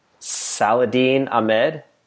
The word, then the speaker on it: Saladin Ahmed: Saladin Ahmed is writing Black Bolt.